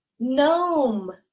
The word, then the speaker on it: gnome: Guess which letter is silent? It is the first letter. We pronounce this as gnome.